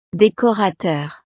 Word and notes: decorateur: frances

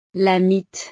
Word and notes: la mite: frances

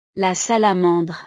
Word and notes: la salamandre: frances

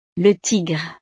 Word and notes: le tigre: frances

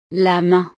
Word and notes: la main: French

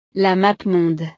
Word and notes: la mappemonde: French

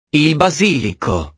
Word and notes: il basilico: italiano

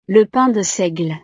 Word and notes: le pain de seigle: frances

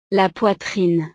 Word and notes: la poitrine: frances

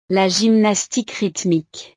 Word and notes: la gymnastique rythmique: French

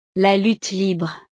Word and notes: la lutte libre: French

